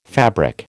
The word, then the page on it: fabric: American English